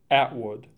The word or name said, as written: Atwood